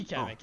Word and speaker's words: Kamek: Rose sounds like Kamek or Comic or however you pronounce that. – Right. It would be Kamek, yeah, probably.